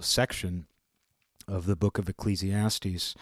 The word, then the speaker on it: Ecclesiastes: however you say it. I believe it's pronounced Ecclesiastes.